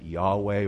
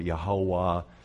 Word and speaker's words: Yahweh: And what they found is that Jesus is, Yahweh is the, you put some vowels in with Yod-Heh-Wav-Heh, and you can pronounce it Yahweh or Yehovah. Jehovah is really not a good name. It actually comes from a Latin transliteration.